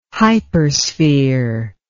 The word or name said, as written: hypersphere